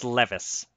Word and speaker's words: Levis: For the record, I believe it's pronounced Levis. That's what I keep Levis. – Hearing. Will Levis.